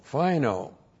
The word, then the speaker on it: phaino: P-H-A-I-N long O, fino.